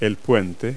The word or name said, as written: bridge